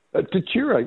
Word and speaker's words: Tatura: And then, of course, Bendigo how do I say it? Tatura? – Tatura, yes, indeed.